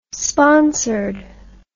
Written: sponsored

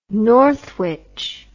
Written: northwich

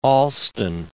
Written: Allston

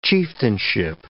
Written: chieftainship

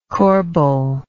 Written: corbeille

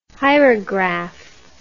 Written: hierograph

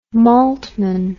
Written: maltman

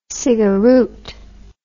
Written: cigaroot

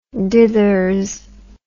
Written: dithers

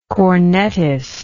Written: cornetist

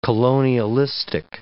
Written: colonialistic

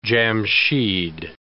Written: Jamshid

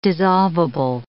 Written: dissolvable

